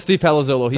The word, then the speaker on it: Palazzolo: Is it Steve Palazzolo? – This is Steve Palazzolo.